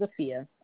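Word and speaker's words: Afiya: My name, first name is Afia,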